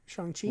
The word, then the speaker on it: Shang-Chi: But tonight I also saw Shang-Chi. Shang-Chi? – Mm. Pronounce that right? – Yeah. And The Legend of the Ten Rings.